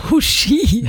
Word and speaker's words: Huschi: Huschi? – Ja, so wird das nämlich richtig ausgesprochen. – Ja, ich finde es dann super, wenn man vorher checkt, dass Huschi ist und sich eine Jacke mitnimmt, weil ich war so am Weg in die Arbeit.